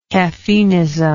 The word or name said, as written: caffeinism